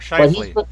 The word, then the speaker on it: Scheifele: Did I even say that right? – Mark Scheifele. – Scheifele. Okay, he's been on a bit of a hot streak, so I may as well ride that a little bit.